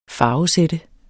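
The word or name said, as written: farvesæt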